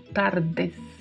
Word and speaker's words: tardes: O DE é pronunciado como de mesmo, tardes. Não se pronuncia com som de di, como acontece em muitas palavras no português.